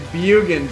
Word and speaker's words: Bjugan: It's Bjugan, drop the J.